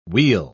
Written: weal

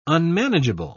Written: unmanageable

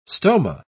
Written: stoma

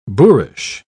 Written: boorish

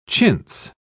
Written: chintz

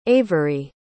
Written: avery

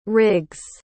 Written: riggs

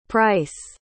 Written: pryce

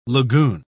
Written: lagoon

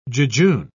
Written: jejune

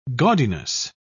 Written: gaudiness

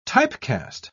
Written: typecast

